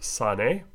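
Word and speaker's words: sane (שָׂנֵא): In the Old Testament, the Hebrew word from there, Strong's 8130, is pronounced sane, and it means to detest,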